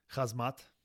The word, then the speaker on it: Khamzat: And then Khamzat Chimaev against Leon Edwards. That's Khamzat, goddammit. Get his name right. – Khamzat, what did I say? – Khamzat, like hazmat suit.